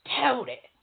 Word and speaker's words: Tutty: And she got the nerve to call me Tutty. No, my name ain't Tutty. It's Tutty. T-U-T-T-Y. That how it's spelled, that how it sounds. And don't you forget it either.